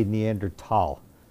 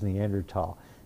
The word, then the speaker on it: Neanderthal: And if you want to really impress people, you say Neanderthal, even though it says Thal. The reason for that is it's German grammar. And in classic German, you don't pronounce the H. So this is pronounced Neanderthal.